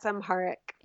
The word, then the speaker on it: Amharic: That's Amharic. – Amharic. Is that how you say it? – Uh-huh.